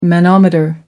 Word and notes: manometer: American English

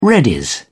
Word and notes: readies: British English

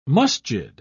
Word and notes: masjid: British English

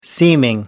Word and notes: seeming: American English

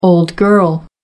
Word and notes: old girl: American English

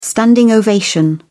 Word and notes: standing ovation: British English

